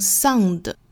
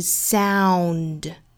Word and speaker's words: sound: 所以这个音不太好发，因为我们汉语里面没有对应的发音呢，我们可能会把它发成 sound，不是 sound，应该是 sound，注意 ow 这个音。